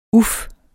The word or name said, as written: uf